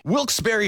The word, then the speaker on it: Wilkes-Barre: Now, I had heard that it was supposed to be pronounced Wilkes-Barre. – Wilkes-Barre Scranton's 97.9X.